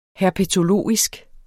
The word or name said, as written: herpetologisk